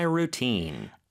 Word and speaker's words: routine: 그 다음에 루틴이라는 단어가 모음 소리가 생각보다 길어요. routine 이렇게 하시면 돼요. ru 발음 길게, teen 이 발음 길게 해주시면 되죠.